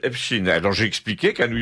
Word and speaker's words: Epstein: Et tout le monde disait Epstein à New York, obstinément. Parce que c'était l'usage. Alors, ce que cet usage vienne du fait qu'il fallait pas, judaïser la prononciation, c'est possible. Mais en tout cas, Epstein est une prononciation parfaitement américaine qui a été reconnue par tous les médias américains dans les années 90-2000.